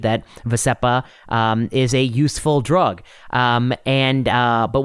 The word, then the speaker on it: Vascepa: The drug in question, as you mentioned, is Vascepa. I think it's called Vascepa. That's how the advertisements pronounce it.